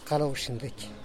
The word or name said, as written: kaloxindeky